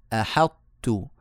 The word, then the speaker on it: أحطت: كلمة أحط طبعا حاء مرققة بعدها حرف مفخم كبير مطبق مستعلي وهو حرف الطاء فقد تتأثر الحاء بقوة الحرف الذي بعدها فوجب التنبيه فصار النطق الصواب أحطت